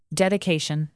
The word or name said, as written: dedication